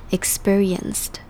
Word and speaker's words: experienced: 这个字第二个要注意的重点是，这边的 p 在这个字之中听起来会像 b，而不是 p 的声音，听起来会比较像字母 b 的发音 experienced。